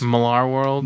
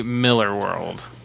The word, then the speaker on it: Millar: Millar World? – Millar World. – It's actually Miller World. – No, it's Millar. – He pronounces it Miller.